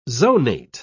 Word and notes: zonate: British English

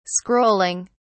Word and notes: scrolling: British English

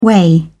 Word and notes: whey: British English

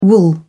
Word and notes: wool: British English

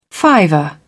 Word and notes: fiver: British English

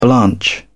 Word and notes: blanch: British English

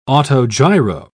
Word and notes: autogiro: British English

